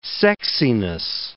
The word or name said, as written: sexiness